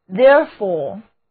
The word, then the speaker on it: therefore: 字母组合 ore 读作长的 o, therefore, therefore 用在表示因果联系的句子当中，连接原因和结果。